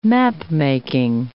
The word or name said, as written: mapmaking